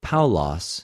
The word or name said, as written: Παυλος